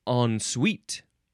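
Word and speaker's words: en suite: Next is ensuite. Yep, that's how you say it. Ensuite, two words. First word, E-N. Second word, S-U-I-T-E.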